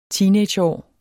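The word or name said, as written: TEENAGEÅR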